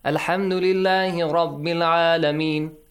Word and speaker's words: الحمد لله رب العالمين: This sound at the end. Did you hear it, Ali Nasir? – Yes. – Alhamdulillahi Rabbil Alameen. This long sound. While there is no mat. Alhamdulillahi Rabbil Alameen.